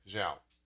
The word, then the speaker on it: Zhao: You said it right. How did you say it? Say it one more time. – Zhao? – Zhao? Okay. Zhao. – How is it spelled? – Z-H-A-O? Yeah. – I think that's Zhao.